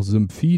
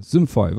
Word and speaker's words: Simfy: Äh, manche sagen auch Simphi, das müsst ihr selber wissen, wie ihr das aussprechen wollt. Geschrieben wird es S-I-M-F-Y. Die deutsche Webseite dazu dann eben mit einem Punkt.de dahinter. Ja, Simfy, was gibt es dazu zu sagen?